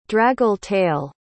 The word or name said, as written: draggle-tail